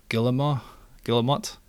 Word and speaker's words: Guillemot: Following this financial report, the Ubisoft CEO, Yves Guillemot, apparently that's what Google told me how to pronounce, because I think I've been pronouncing it wrong,